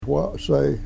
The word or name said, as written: wasey